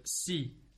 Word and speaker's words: scie: Well, a little offshoot from Saw is, if you want to say an actual Saw in French, is the word si. S-C-I-E. – Si. – It's pronounced si.